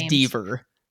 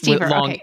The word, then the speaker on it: Deaver: It is Deaver. – It is Deaver. – Long E.